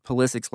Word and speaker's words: Pulisic: In Germany, everyone pronounces Pulisic's last name Pulisic, the way it would be in Croatia, the birthplace of his grandfather Mate.